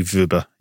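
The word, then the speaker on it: Wöber: And we listened to a pronunciation of it, and it seems to be Vuber.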